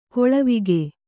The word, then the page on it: ಗಾಲಿವಕ್ಕಿ: gālivakki